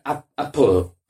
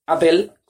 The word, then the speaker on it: Apple: Y la P yo creo que se pronuncia con una especie de entre E y O. En plan, Apple, apo. Es como decirlo sin la vocal. Entonces, yo creo que en España se dice Apple con E más por porque se parece más, ¿no?